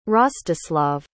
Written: Rostyslav

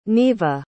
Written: neiva

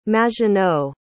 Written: maginot

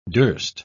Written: durst

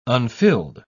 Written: unfilled

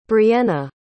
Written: brienna

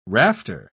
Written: rafter

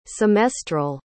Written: semestral

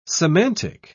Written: semantic

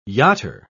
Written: yachter

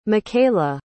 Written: makayla

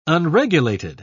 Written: unregulated

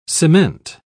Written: cement

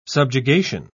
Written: subjugation

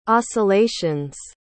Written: oscillations

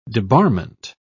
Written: debarment

